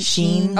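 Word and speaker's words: Shein: Is that how you say it? – Shine. Sheen. – Oh, shine. – In my head, yo digo Shane.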